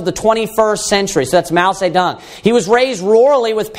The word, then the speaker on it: Mao Zedong: And his name is Mao Zedong. Mao Zedong, you can pronounce it. Sometimes it's pronounced in English. But Mao Zedong is how it's pronounced in Chinese, in China.